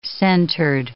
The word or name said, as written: centered